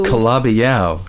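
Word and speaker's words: Calabi-Yau: The thing with the hyphen that ends with Y-A-U. – Kalabiyau.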